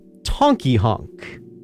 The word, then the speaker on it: Tonky Honk: That's the sound of Tonky Honk. And no, I am not mispronouncing Honky Tonk.